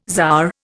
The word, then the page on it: czar: Indian